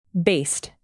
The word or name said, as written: based